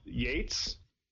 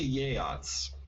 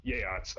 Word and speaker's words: Yeats: Is it Yates? Yeats? – It's actually Yeats. – Yeats.